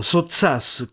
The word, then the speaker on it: Sottsass: Quell'uomo fu Ettore Sottsass, per Nanda Ettorino, ma in pubblico Sottsass con l'accento rigorosamente sulla A.